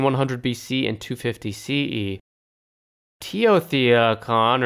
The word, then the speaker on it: Teotihuacan: Teotihuacan.